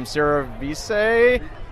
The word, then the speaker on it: cerevisiae: I'm going to butcher this name, Cerevisiae? – Close enough.